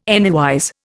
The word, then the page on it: anywise: Indian